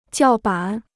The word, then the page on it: 叫板: jiào bǎn